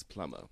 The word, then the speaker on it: plumber: It's plumber. – Okusala umvilubutin. – Anyway, jokes aside, if you're plumber... – It's plumber. – Plumber. – Yes. – So I must pretend there's no P. It's a silent P.